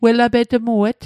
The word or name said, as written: welapetmuet